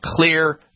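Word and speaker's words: Xlear: And clear is spelled with an X, X-L-E-A-R.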